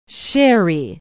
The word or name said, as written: Sherry